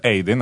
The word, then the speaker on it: Aidan: Si pronuncia Aiden, anche se si scrive Aidan.